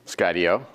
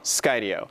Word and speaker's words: Skydio: So how do you pronounce the company name? – Skydio. – Skydio.